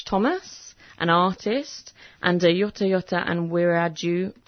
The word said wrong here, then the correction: Wiradjuri: Wiradjuri, yeah.